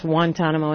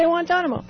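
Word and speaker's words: Guantánamo: I don't say Guantanamo, by the way, because I grew up in Puerto Rico and it is pronounced Guantanamo in Spanish.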